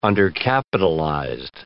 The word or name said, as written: undercapitalized